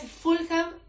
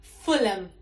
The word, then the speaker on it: Fulham: Y por último, no es Fulham, es Fulham. La H no se pronuncia.